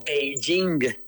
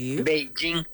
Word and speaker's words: Beijing: P-E-K-I-N. Cuando la capital es China, se pronuncia Beijing. Beijing. – Correcto.